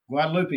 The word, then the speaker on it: Guadalupe: It could either be Guadalupe, Guadalupe. – Guadalupe. – Yes. Okay. That's what I thought, but I didn't know how Texans pronounced it.